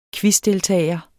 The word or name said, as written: quizdeltager